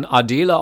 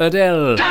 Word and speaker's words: Adele: Und als dieser Song im Jahre 2011 in den Charts stand, da glaubten ja noch viele, die Sängerin, die müsse man Adele aussprechen. Aber nein, heute weiß es jeder, sie ist ein Weltstar und sie heißt Adele.